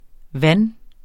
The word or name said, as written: vand